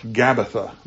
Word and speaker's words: Gabbatha: The fifth and final point is Gabbatha, that's spelled G-A-B-B-A-T-H-A, Gabbatha, You might not be familiar with the name, but it's actually, this is the last stop before Jesus went to the cross.